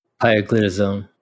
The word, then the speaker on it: pioglitazone: Is it pio? – Pioglitazone. I know that one because I sold it.